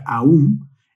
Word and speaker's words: Om: Y es que de acuerdo a los textos sagrados, la sílaba Om, misma que se pronuncia Aum, es el sonido universal o divino.